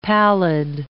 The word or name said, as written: pallid